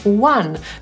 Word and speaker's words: won: To słowo czyta się dokładnie tak samo jak liczbę 1, czyli właśnie one.